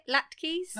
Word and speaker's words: latkes: We also had Addy, who wanted to tell us that his favourite thing at Christmas is latkes. Is that how you say it? Latkes? – Latkes.